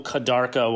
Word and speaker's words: Kadarka: It could be I tasted actually really quirky, cool Kadarka wine yesterday. – Spell that. – K-A-D-A-R-K-A, Kadarka.